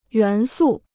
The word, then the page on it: 元素: yuán sù